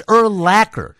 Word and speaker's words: Urlacher: They say Urlacher. It's Urlacher. And it's so easy to remember because it rhymes with linebacker.